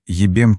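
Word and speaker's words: jebem: «Джебем тиматер» произносится как «ебем тиматер», а в переводе на английский выглядит как «ай лфек юрмезер», одно из наиболее распространенных вульгарных ругательств.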